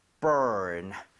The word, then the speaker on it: burn: 얘도 발음할 때 r 살아있죠. – 예. – 그래서 아까 warn처럼 혀를 당기고 은 하고 아하. – finish 하셔야 돼요. 자, 해볼게요. burn, burn.